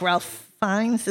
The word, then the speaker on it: Ralph Fiennes: I really like Ralph Fiennes. Is that how you pronounce it? – Yeah.